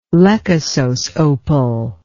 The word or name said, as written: lechosos opal